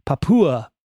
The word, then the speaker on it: Papua: But instead, I'm trying to pronounce this word that's spelled P-A-P-U-A, Papua.